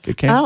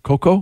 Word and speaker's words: cacao: How do you say that? Cacao? – Oh, cacao. – Cacao. – Cacao. – Cacao. Cacao. Cocoa? – Cocoa. – No, it's cacao.